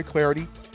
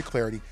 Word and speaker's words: Clardy: Don't forget, follow me on Twitter, at Troy Clarity. Last name is spelled C-L-A-R-D-Y, at Troy Clarity. You can also subscribe, rate, and review the TreeCast with Troy Clarity on Apple Podcasts, Google Play, and pretty much anywhere you get your favorite podcast from.